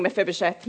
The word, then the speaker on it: Mephibosheth: I've been going around at home going, Mephibosheth, Mephibosheth, Mephibosheth.